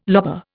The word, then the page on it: lobar: Indian